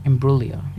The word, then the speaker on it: Imbruglia: Imbruglia. Yep, it's pretty close. Good. – How do you say it? – Imbruglia. – Imbruglia. – Yeah. Yep.